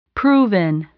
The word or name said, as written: proven